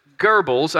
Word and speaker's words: Goebbels: Adolf Hitler was the leader of Germany's Third Reich, but Joseph Goebbels, I know that's a weird pronunciation, the way that the name is spelled, but that's how you pronounce his name.